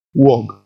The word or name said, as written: wog